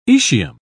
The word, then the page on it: aecium: British English